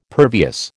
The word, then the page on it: pervious: American English